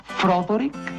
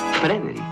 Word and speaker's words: Frederick: ¿Dice usted también Froderick? No, Frederick. ¿Y por qué no es Froderick Frankenstein? Porque no, es Frederick Frankenstein.